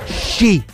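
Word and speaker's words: sci (四): Perché si pronuncia sci, tipo la canzone dei Green Day, sci.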